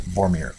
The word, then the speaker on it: Vormir: The planet Vormir, I had mispronounced it correctly. That's the planet which Red Skull goes to. So it is the actual planet Vormir.